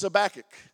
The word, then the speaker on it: Habakkuk: People call him Habakkuk. People say Habakkuk. The correct pronunciation is Habakkuk. How do you know that? Because I'm holding the microphone.